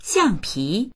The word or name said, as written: xiang4pi2